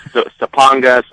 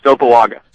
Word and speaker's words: Sapuoga: And he was the resounding answer, but none of the players could get his pronunciation right. It was Sapanga, Sapuoga. They were throwing lots of N's in there, but I do believe it is Sapuoga. – Sapuoga it is, and that's what we're staying with.